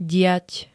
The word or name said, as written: diať